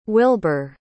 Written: wilber

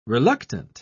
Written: reluctant

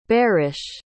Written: barish

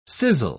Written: sizzle

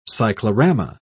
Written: cyclorama

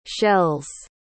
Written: shells

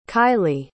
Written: kaili

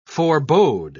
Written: forebode

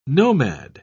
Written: nomad

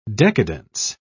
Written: decadence

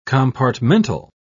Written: compartmental

